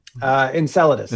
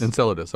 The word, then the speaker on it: Enceladus: Am I saying that correctly? – Enceladus. – Enceladus.